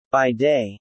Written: By day

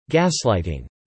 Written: gaslighting

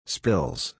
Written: spills

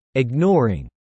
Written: ignoring

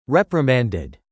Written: reprimanded

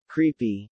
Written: creepy

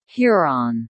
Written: Huron

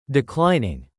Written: contributes

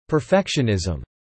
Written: perfectionism

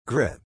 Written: grit

